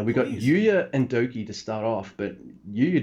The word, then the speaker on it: Douki: I've taken to spelling Doki, not D-O-U-K-I, how it's spelled, but D-O-K-I, just so I don't keep driving Dave Howard fucking insane.